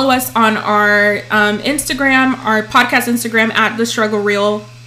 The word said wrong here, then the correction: The Struggle's Real: So it's follow us on our podcast Instagram at The Struggle's Real.